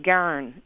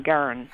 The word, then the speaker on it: Gawron: It's been up to debate for years, but Garn is usually what we say. G-A-W-R-O-N, Garn.